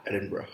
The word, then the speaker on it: Edinburgh: And am I pronouncing that? Edinburgh. – Edinburgh. – Edinburgh. – You can try your British accent.